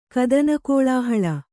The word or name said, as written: ಕದನಕೋಳಾಹಳ